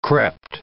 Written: crept

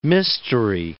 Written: mystery

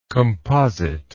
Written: compositeness

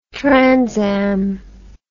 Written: transam

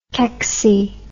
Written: kecksy

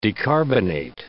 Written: decarbonate

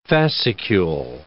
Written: fascicule

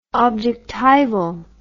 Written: objectival

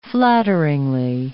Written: flattering

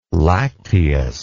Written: lacteous

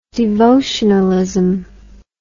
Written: devotionalism

